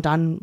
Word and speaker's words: Donne: John Donne loved poetry. – What? How does he pronounce it again? – Don. John Donne. Like John Don Jovi.